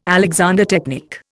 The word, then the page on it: Alexander technique: Indian